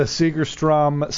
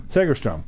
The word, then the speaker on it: Segerstrom: Sorry, you're going to miss it because you'll be on stage at the Segerstrom Center for the Arts. Did I say that right? – Segerstrom. – What was wrong with my way? Strom.